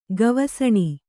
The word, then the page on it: ಗವಸಣಿ: Kannada